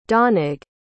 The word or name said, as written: donagh